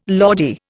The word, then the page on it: Lordy: Indian